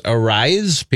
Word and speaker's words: Arraez: who we found, I guess we found out the actual correct way to pronounce his name is Arraez.